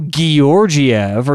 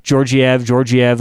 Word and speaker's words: Georgiev: The Avs pronouncers love to go Georgiev or Georgiev because they've been hitting the hard G this year.